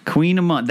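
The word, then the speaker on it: Kouign-amann: That word is Queen Amon. – Queen Amon. That's how you say Queen Amon. – Yeah. It's spelled like Koigan Ammon.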